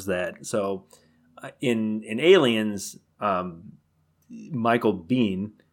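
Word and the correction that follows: Biehn: Bean is that how do you say his name? Michael Bean. Michael, I call him Michael Bain, but Bane.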